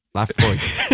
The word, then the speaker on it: Laphroaig: Lefroy is spelled LAPHROAIG. – Yes. – I had to read that off the bowl because I can never get it right. But it is, we are pronouncing that right. It says on the back. Lefroy.